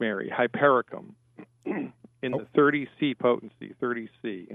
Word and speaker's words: Hypericum: The medicine that she wants is called Hypericum. – You said Hyperifen? – Yeah, I'll spell it for you.